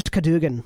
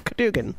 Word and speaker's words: Cadwgan: The name is Cadogan. the other gnome says. Because it's spelled C-A-D-W-G-A-N. Kadugan. Yeah, Kadugan.